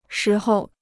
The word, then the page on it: 时候: shí hou